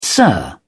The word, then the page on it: sir: British English